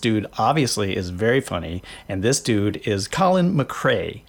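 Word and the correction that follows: Mochrie: Did I get it wrong? – Mockery. – God. – Mockery. 2 syllables.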